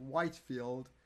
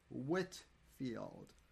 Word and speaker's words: Whitfield: It's not Whitefield. It's Whitfield. Like I have a sharp wit about me.